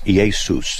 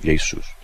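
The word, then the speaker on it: Iesus (Ἰησοῦς): In the Greek, it's Iesus. That's how you pronounce it, Iesus, okay? – Right.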